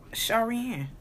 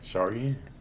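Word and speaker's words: Charyanne: Sharian. – Yeah, Sharian that that's kind of interesting.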